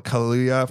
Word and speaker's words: Kaluuya: Daniel Kaluuya for Get Out.